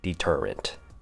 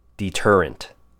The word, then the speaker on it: deterrent: Next word, deterrent. D has the long E sound, ter as in T-E-R, and unt. I know you guys want to say rent at the end, but try and aim for the unt sound. Deterrent. Deterrent.